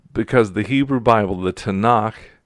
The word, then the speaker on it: Tanakh: Three letters, Tet, Nun, and Kaf, Tanakh, if you were to pronounce them.